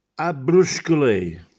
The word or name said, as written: Abbrusckelè